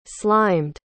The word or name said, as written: slimed